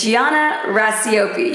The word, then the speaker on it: Gianna Racioppi: The correct way to say it is Gianna Racioppi.